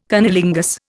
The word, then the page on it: cunnilingus: Indian